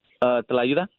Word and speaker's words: Tlayuda: Tlayuda. – So I'm saying it wrong. So the T is not silent. – No, it's not. It's not per se silent, but yeah, it's, the T is very pronounced.